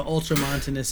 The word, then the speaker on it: ultramontanist: But I think that soon, that ultramontanist, did I say that right? Ultramontanist, yeah.